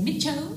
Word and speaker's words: Miss Chu: One, don't pronounce the S, yes. – Miss Chu. So in the Vietnamese accent, you would say, Mit Cho.